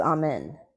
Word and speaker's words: Amen: Or no, I think it's Amen. That's how you pronounce it.